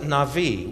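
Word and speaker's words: Navi (נביא): It's spelled N-A-B-B-I, but in the Hebrew language, you pronounce it Navi, with V's, victor sound. N-A-V-V-I, a Navi.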